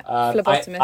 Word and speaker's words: phlebotomists: Yeah, phlebotomists.